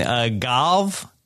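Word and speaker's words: Gävle: Galv? – Yeah, Danny's got it.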